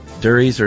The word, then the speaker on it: durries: Durries or cigarettes rhymes with curries.